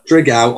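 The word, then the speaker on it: Dragão: It's now being played at the Stadio de Drago in Porto. Excuse the pronunciation. It was all right, though, fellas, wasn't it? – Not too bad. Drigao.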